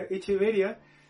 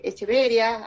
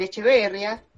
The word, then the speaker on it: Echeveria: Eh, claro, aquí la mayoría les dice Echeveria, algunos les dicen Echeverria, que esa es totalmente la forma incorrecta, digámoslo, y, pero la pronunciación es Echevequia.